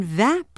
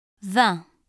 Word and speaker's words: vingt: The final consonant of 20 is silent when it stands alone or before a noun beginning with a consonant. When vingt precedes a noun beginning with a vowel sound and when it is combined with another number, the T is pronounced.